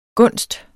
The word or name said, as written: gunst